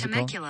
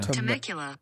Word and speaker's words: Temecula: What is Temecula. – It called? Temecula, California. – Temecula.